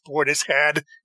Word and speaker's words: Portishead: And yes, it's pronounced Portishead. You can look it up.